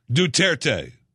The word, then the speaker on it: Duterte: Do you have the pronunciation of Duarte? Duterte. President Rodrigo Duterte. I knew I was saying that wrong.